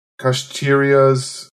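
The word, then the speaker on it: Kshatriya: He says, the duties of Brahmins, kshatriyas I get. I, can you tell me how to pronounce those words? Yeah. So Brahmins are the priests. Kshatriyas, yeah, are the warriors.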